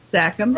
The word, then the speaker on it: Sachem: S-A-C-H-E-M. Sachem? – Probably Sachem, yeah.